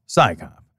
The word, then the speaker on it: PSYCOP: Its acronym, PSYCOP, is pronounced PSYCOP, as if it's an organization of scientists performing a police function.